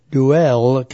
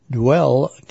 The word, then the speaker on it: Deuel: And on July 25th, Duell County ARC Hamfest. That's spelled D-E-U-E-L. Duell County ARC Hamfest.